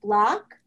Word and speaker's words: Bloch: Google my name, but my last name is spelled with an H at the end. So even though it's pronounced Block, it is spelled B-L-O-C-H. And so just Google Helen Block and you'll find me.